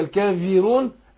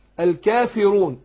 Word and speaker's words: الكافرون: اقول في الكافيرون، الكافيرون لا ما تنفعش. يبقى اقول ايه؟ الكا الكافي في في في، الكافرون الكافرون. يبقى اقول قل يا ايها الكافرون، رون رو رو رو. ناخد بالنا الراء مفخمه وعرفنا بننطقها ازاي، الكافي رو رون.